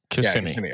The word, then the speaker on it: Kissimmee: It's Kissimmee, yeah? – Kissimmee.